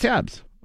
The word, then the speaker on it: Jabs: Matthias Jobs is... – Matthias Jobs. – Is that how you say his name? – Matthias Jabs of the Scorpions.